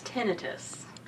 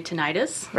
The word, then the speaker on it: tinnitus: To be correct, it's tinnitus. – Tinnitus. – And so a lot of people, you'll hear them say tinnitus.